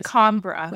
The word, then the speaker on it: Lacombra: Lacombra. – Lacombra. – All short A's.